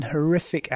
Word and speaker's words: horrific: An horrific accident. Again, horrific, horrific, stresses on the second syllable, not the first.